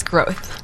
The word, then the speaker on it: Groth: It's Groth. – Valerie Groth, G-R-O-T-H.